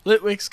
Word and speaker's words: Litwick: Litwick.